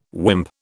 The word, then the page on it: wimp: British English